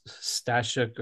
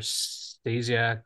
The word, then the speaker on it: Stasiuk: So I kind of I've got a professional one too, which is just JM underscore STASIUK. My last name is Stasiuk or Stasiak or Stasiuk. I actually don't know how to say it.